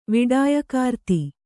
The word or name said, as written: ವಿಡಾಯಕಾರ್ತಿ